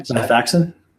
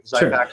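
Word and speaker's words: Xifaxan: Zyfaxan. – Thank you. Zyfaxan.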